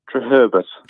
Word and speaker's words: Treherbert: Treherbert.